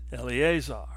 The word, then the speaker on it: Eleazar: In Hebrew, his name is pronounced Eleazar, which means the one Elohim helps.